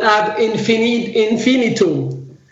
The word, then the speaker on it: Ad Infinitum: Is that how I say it? Ad Infinitum? – Yes, it's called Ad Infinitum. – Ad Infinitum. There we go. I was looking for where the emphasis should go on my syllables there.